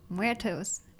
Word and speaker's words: Muertos: Dia de Muertos? – Oh, my gosh. – I'm sure I said that wrong. – Muertos. – But anyway, they go there and they hold like ceremonies and celebration there.